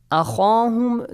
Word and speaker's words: أخاهم: So, أخاهم, the word أخاهم, خا is a heavy letter, Hassan, yeah? – Yes. – You gotta pronounce it how? – خا.